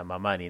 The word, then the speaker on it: Mamani: se pronuncia con el acento en la penúltima sílaba, entonces justamente sería Mamani, ¿no?